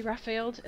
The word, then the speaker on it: Rafeld: Betsy Raffeld, is that how you say it? R-A-F-E-L-D? Raffeld? – Raffeld, yes.